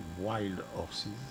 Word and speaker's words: Wild Horses: Et puis dernier groupe qui va clôturer ce 39e festival, c'est Wild Horses. C'est comme ça qu'on prononce ? – The Wild Horses. – J'étais bon ou pas ? – The Wild Horses. – Presque. Wild Horses.